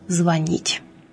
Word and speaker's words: звонит: Сегодня мы поговорим об употреблении глагола «звонить». Этот глагол входит в группу глаголов на «ить» с так называемым неподвижным ударением, то есть относится к глаголам, в которых ударение во всех личных формах падает на окончание.